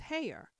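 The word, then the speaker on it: Haigha: His name is Heyer." – He pronounced it so to rhyme with mayor.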